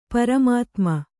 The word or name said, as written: ಪರಮಾತುಮ